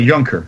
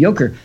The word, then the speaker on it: Juncker: Assuming that you're right, Juncker. – Yeah, the German would be Juncker.